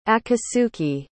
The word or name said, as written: akasuki